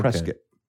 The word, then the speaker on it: Prescott: It's spelled Prescott. It's pronounced okay. – Prescott. – Prescott. Okay.